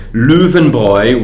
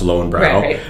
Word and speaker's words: Löwenbräu: Löwenbräu, which most Americans pronounce Löwenbräu.